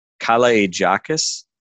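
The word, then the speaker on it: Kalaitzakis: Someone posted that it's pronounced Kalaijakis.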